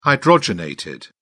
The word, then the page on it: hydrogenated: British English